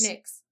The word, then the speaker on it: Nyx: Nyx. Nyx. Nyx. – Like Stevie Nicks.